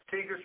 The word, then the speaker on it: Teager: Tiger spell it? – T-E-A-G-E-R.